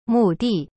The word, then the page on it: 目的: mu di